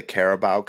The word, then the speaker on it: Carabao: And it's not called the Caribou Cup. It's called the Carabao Cup.